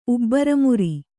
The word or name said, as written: ಉಬ್ಬರಮುರಿ